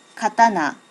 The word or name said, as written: katana